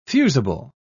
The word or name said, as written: fusible